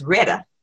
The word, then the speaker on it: Rheta: and my mother's name is Rheta, spelled with an H R H E T A. Uh, I'm understanding now in the South that's Retta.